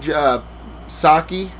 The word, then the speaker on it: Psaki: what's her name, Psaki? – Silent P.